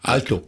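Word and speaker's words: Aalto: Szervusz, Mert ugye az óriások mellett most, még akiről nem volt szó, Álvar Ato, Álvar Ato? Hogy Alto. Simán így kell kiejteni.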